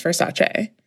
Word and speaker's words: Versace: But I would say the majority of people don't know that it's pronounced Versace. – 99.9% of Americans pronounce it Versace.